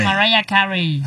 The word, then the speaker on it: Mariah Carey: Cristina Aguilera, María Carey, ¿o cómo se dice? ¿Sí, no? María Carey. – Mariah Carey.